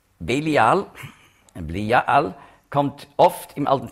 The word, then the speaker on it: Belial: Das hebräische Wort Belial spricht man aus Bli-Ja-Al.